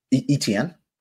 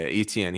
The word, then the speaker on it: Etienne: Etienne? How do you say his name? – Etienne. – Yeah, Etienne.